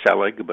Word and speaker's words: Selig: I usually say Selig, but either is fine.